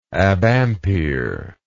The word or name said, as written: abampere